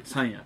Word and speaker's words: 山谷: ちなみに山谷って山の谷と書いて山谷って読むみたいですね、